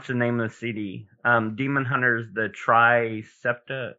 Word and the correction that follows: Triptych: tri. Um, you know what one I'm the talking. Triptych. – Yeah, the triptych.